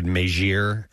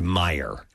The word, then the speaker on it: Meijer: A lot of people are texting and saying I mispronounced, I said Meijer is the name of the store. It's Meijer.